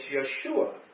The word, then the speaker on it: Yeshua: But the Jews don't pronounce the J, so it's Yeshua is the correct pronunciation for Jesus.